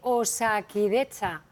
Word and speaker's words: Osakidetza: Casi 48.000 personas se van a examinar hoy en las oposiciones sanitarias de Osakidetza, el Servicio de Salud Vasco. No sé si lo he dicho bien, la verdad. – Seguramente, seguramente que lo has dicho bien.